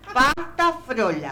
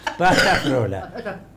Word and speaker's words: pasta frola: La pasta Flola que hace... – Flola. – Frola. – Dijo Lola. – ¿Cómo se dice? – Pasta Frola. – ¿Y qué dije yo? – Pasta Flola. – Esperá que me acomodo. Pasta Frola.